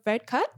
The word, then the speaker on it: Varadkar: Boris Johnson met with Leo Varadkar. Did I say it right? Yes.